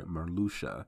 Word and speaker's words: Marluxia: And I especially want to know what Malarxia, what he came, or Lurium, excuse me, what he came to terms with, or I keep saying Malarxia, I'm sorry, Marluxia. I've